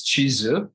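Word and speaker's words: chizu (チーズ): Well, by the way, the Japanese word for cheese is chizu. – Now you tell me. Okay. – An important word because they don't have it indigenously. So they use the English word for it with a Japanese pronunciation.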